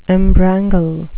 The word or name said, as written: embrangle